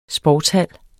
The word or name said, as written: sportshal